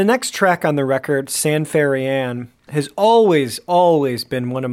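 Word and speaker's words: ça ne fait rien: Well, it's pronounced San Ferian, maybe San Ferian. – Yeah, San Ferian.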